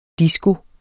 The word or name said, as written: disko